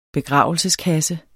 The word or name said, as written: begravelseskasse